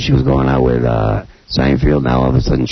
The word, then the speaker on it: Seinfeld: It's Seinfeld. – Seinfeld.